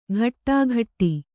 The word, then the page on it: ಘಟ್ಟಾಘಟ್ಟಿ: ghattāghaṭṭi